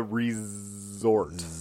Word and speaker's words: Rezort: And this week, we are talking about The Resort. Emphasizing the midsection because it's spelled with a Z. – A Z, not an S.